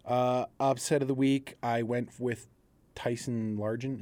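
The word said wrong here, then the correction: Bagent: Is that what his name is? – Bajent. – Tyson Bajent. I get his name wrong. – Yeah, I don't think you've said his name right.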